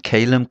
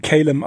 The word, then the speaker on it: Kalem: Kalem Club oder auch KLM Club ausgesprochen. Da scheiden sich mhm. so ein bisschen die Geister, oder? Da scheiden sich die Geister. Ich mhm. finde Kalem aber. Das ist ein bisschen flüssiger.